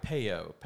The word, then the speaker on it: Peyo: Uh, I always think I always pronounced it Payo. Uh, I think it's P E Y O, right? Right, Payo.